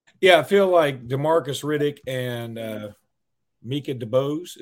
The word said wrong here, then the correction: Micah DeBose: Is that, my? am I saying that right now? – Micah DeBose.